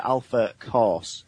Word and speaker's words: Alpha Course: The Alpha Cause? – It's the Alpha Course. So course is in like a course at university or something like that.